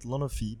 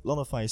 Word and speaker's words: Lonofi: How do you say it, and what does it mean? – Well, I mean, in French we say it Lonofi, but Lonofi is pretty good as well.